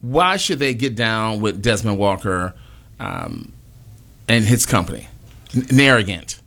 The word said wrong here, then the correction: Narratent: Am I saying that right? – Narratent. – You were thinking about yourself. Arrogant. Narratent.